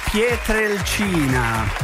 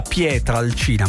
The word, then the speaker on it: Pietrelcina: Per molti ha detto Pietrelcina, compreso Giuseppe Davelli, regia, e detto bene perché si dice pietre e non pietra. Però per molti è diventato pietra, che ora è ammesso la pronuncia sbagliata, ma è Pietrelcina.